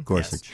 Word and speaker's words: Gorsuch: I'm going to go with that's not the right way to say it. – Yeah, that him, right? – Yes. – Gorgeous. – Okay. – The 10th Circuit judge, mm-hmm. Gorsuch. Yes.